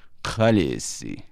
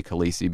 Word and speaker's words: Khaleesi: Consequently, the native Dothraki pronunciation that I came up with was Khaleesi, like that. Khaleesi. And so, in fact, if you were like just an English speaker and you heard that word, you probably would pronounce it Khaleesi, mm-hmm. right? But instead, of course, any English speaker is going to look at that word and say Khaleesi because the EE with us is so strongly associated with the nice E sound like that.